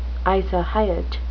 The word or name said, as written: isohyet